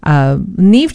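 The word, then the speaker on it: Neave: That's Neve spelled N-E-A-V-E. I've been wondering how to pronounce that.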